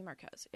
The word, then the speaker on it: Marquez: Mimi Marquez, is that how you pronounce her last name? Marquez? – Mimi Marquez, yeah.